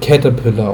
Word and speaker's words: Caterpillar: Und es wird halt Caterpillar ausgesprochen. Halt wie, ich glaube, Raupe oder so auf Englisch.